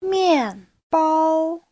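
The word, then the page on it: 面包: mian4bao1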